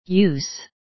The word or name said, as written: use